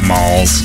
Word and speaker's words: Molls: M-O-L-L-S. – Pronounce that malls. – But when you say that, I think of like a shopping No, like mall. Like the name Molly with the Y off of it.